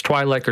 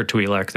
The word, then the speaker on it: Twi'lek: So it's Twi'lek or Twi'lek. They're both right.